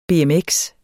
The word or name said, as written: BMX